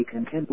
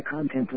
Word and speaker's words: content: For instance, I am very content with the content of this message. See? The word content was spelled the same way but pronounced differently.